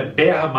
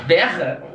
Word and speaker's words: bear: E a próxima é bear market. – Berra? – Bear.